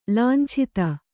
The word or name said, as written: ಲಾಂಛಿತ